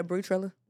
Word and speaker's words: Bri Trilla: Yeah, Bree Trilla.